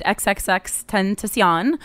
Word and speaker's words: XXXTentacion: She's a uh, Arab Muslim comic, and um, she told a joke about a deceased rap artist named XXXTentacion.